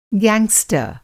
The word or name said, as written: गैंगस्टर